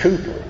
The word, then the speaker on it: Cowper: If you look in your hymnal at the authors of songs in there, you'll find a man named William Cowper. It's spelled C-O-W-P-E-R, but it's actually pronounced Cooper.